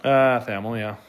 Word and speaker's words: Thamel: Okay, so Pete Thamel, did I mispronunciate that? – Thamel, yeah.